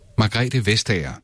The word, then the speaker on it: Margrethe Vestager: Margrethe Vestager.